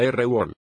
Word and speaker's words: RWAL: La versión de General Motors y Chrysler se llama Rear Wheel Antibloqueo, abreviado RWAL, se pronuncia R-Wall.